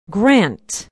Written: grant

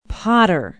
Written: Potter

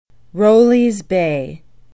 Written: rowleys bay

